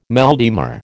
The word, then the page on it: mal de mer: American English